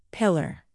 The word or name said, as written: pillar